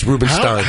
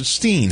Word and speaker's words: Rubenstein: Stein looks like Stein. It's pronounced Stein. – Yeah. – But there are some who pronounce it Steen. – Yeah. – How do you know? Is there a proper... – Yeah, nobody pronounces it Steen. – Yeah. Nobody does. – Well, when it's S-T-E-I-N only, yes. – It's not Steen. – It's Stein. – It's Stein. – It should be Stein.